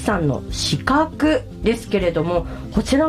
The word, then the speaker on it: 鹿の角: はい、オンラインショップで販売されている家具屋さんということですけれども、鹿の角と書いて四角と読むお名前だそうなんですけれども、こちらの由来、意味っていうのはどういったところにありますか。 – はい、ブランド名は漢字で鹿の角と書いて四角と読むのですが、